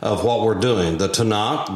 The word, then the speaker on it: Tanakh: and that is T-N-K. And the dominant vowel in that pronunciation is A, so it's Tanakh.